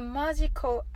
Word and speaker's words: magical: ですはい A magical adventure for you はいこれは横にカタカナを書いておりますがマジカルなのですがマジコと発音してください